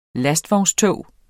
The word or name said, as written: lastvognstog